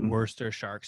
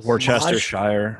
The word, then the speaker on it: Worcestershire: Worcester Shark sauce. – Worcestershire.